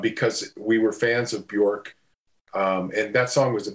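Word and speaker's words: Björk: Bjork, is that how you pronounce that? – Bjork song.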